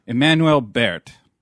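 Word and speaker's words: Emmanuelle Béart: Emmanuel Baird.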